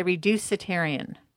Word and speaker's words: reducitarian: And am I saying it right, the reducitarian? – Yeah, you are saying it right. Well, yeah, you're absolutely correct.